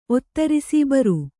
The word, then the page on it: ಒತ್ತಿರಿಸು: ottirisu